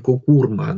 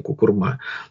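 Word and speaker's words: куркума: что мы, оказывается, неправильно делаем ударение, кукурма она, а не кукурма.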